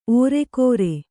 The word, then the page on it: ಓರೆಕೋರೆ: ōreko1re